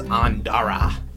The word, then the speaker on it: Ondara: Ondara.